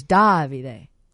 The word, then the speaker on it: Davide: And the one we punched in, it said Davide. – Davide. – And but he kept tweeting at me that it was Davide. – Davide. Oh, because you were putting the emphasis on the wrong syllable, huh? – Yes, but there are places where apparently it's pronounced the other way. Anyway, mm-hmm. So it's Davide with an E at the end.